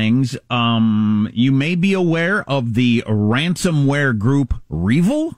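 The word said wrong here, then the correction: REvil: Is that the way you pronounce it? It's capital R, capital E, V-I-L. How do you pronounce it? – Or is it R-Evil? – R-Evil, yeah. Is that the way they say it?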